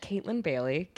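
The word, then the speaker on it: Kaytlin Bailey: It's at Caitlin Bailey, KAYTLIN, because my parents spelled my first name wrong.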